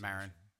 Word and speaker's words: Maron: You're saying Maron, I was like. – Did I say Maron? I don't remember. That's his pronunciation. – Maron.